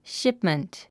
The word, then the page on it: shipment: ʃípmənt; シップメント